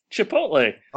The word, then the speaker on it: Chipotle: Wait, stop. Was that a serious pronunciation, or was that a joke pronunciation? – We must correct this immediately. – No, I'm joking. I'm joking. – Okay. – How do you say the restaurant? – Chipotle. – Oh, thank God.